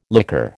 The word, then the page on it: liqueur: American English